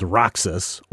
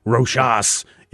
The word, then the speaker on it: Roxas: The space pirate guys, the space motorcycle gang, is led by a guy called Roxas, or Roshas, if you want to go with an alternate pronunciation. – People say Roshas, but I look at that and I'm like, Roxas, you know, like...